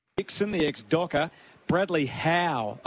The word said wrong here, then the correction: Hoff: I believe it's pronounced. Hoff, I believe it's announced.